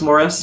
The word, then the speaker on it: Maurice: It's spelled Maurice, but it's pronounced Morris.